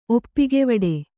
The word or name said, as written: ಒಪ್ಪಿಗೆವಡೆ